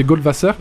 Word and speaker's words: Goldwasser: Je vais le prononcer à l'allemande parce que je pense que c'est allemand, mais c'est Goldwasser.